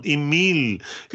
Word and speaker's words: Emil: Även mitt namn, Emil, uttalar du med lite fransk klang.